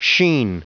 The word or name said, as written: sheen